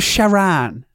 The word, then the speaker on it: Sharan: Yeah, the Volkswagen Sharon. Well, Sharan. – Yeah. Yeah, Sharan.